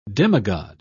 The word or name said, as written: demigod